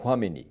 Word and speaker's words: Chouameni: Chomini.